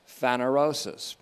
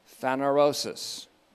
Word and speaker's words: phanerosis: But there's a word that is similar to it, and it can even pass as a synonym for it, and that's phanerosis, P H A N E R, long O S I S, phanerosis.